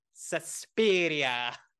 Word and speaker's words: Suspiria: Which, you have to say it like that, Suspiria.